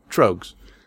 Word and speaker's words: Trogues: It's Trogues, like rogues with a T, Trogues.